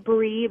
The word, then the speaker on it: Brhe: So yeah, my name is Bree, B-R-H-E.